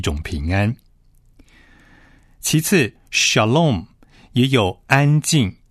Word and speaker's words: shalom: 平安这个词，希伯来的原文念作 shalom。